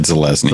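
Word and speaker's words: Zelazny: Dick, Roger Zelinsky, Roger Zelezny, right? That's how you pronounce it. – I think it's Zelezny. I've always said Zelezny.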